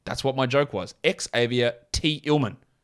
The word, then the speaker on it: Xavier Tillman: I know that's not how it's said. His name, as you can read on the screen, is Xavier Tillman.